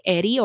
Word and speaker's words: Heriberto: That's Clueless, spelt K-L-O-O-G-H-L-E-S-S. – So now we're going to get into Seda's early life. Heriberto Seda, a.k.a. Eri, or Eri, again, the H is silent, and when the two R's...